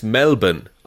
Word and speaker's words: Melbourne: The other one, Connor, is pronounced Melbourne, I'll have you know. – Of course, there's no R in it, everyone knows that.